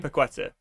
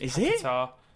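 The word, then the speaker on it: Paquetá: I know how to pronounce his name. – Paqueta. – That's it. I'm not even taking the piss out of you. It's Paqueta. That's the only.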